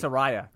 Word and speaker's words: Saraya: Saraya.